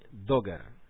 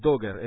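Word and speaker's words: Dugger: El autor se llama Andrew de Andrés, en inglés, Andrew, se escribe Andrew con W al final, y el apellido es Dogger, es D-U-G-G-E-R. D de dedo, U, G de gato, doble, una G doble, después ER, Andrew N.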